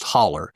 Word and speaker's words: hollow: And also, guys, it's spelled H-O-L-L-O-W, but it's pronounced holler. If you pronounce that hollow, you'll get kicked out of anywhere that you're saying that word.